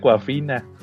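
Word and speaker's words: Awkwafina: Tiene un nombre bien raro, pero según entiendo se, se, pronuncia Coafina.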